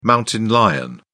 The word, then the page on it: mountain lion: British English